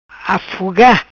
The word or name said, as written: Afuga